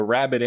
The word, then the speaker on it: rabid: Now, rabid has a short A and a short I sound, rabid. But rabies has a long A and a long E sound, so the two are quite a bit different in that respect. Rabid and rabies.